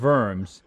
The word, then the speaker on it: Worms: It was called the Diet of Worms, or you'd read it and say the Diet of Worms, but it's pronounced Diet of Worms.